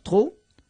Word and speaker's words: trop: D'accord, trop.